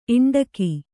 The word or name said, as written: ಇಂಡಿಗೆ